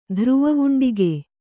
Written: ಧ್ರುವವುಂಡಿಗೆ

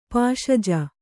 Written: ಪಾಶಜ

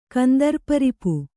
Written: ಕಂದರ್ಪರಿಪು

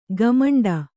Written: ಘಮೆಂಡಿ

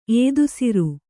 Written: ಏದುಸಿರು

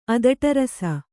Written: ಅದಟರಸ

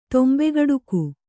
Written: ತೊಂಬೆಗಡುಕು

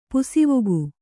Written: ಪಾಸುವೊಕ್ಕು

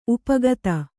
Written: ಉಪಕಥೆ

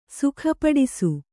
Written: ಸುಖಪಡಿಸು

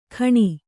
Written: ಖಣಿ

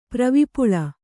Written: ಪ್ರವಿಪುಳ

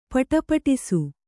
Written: ಪುಟುಪುಟಿಸು

